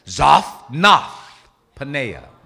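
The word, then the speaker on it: Zaphnath-paaneah: Say that, Zaphnath. Okay, Zaph, Zaphnath, Zaphnath Paneah. That's how it's pronounced. Zaphnath Paneah.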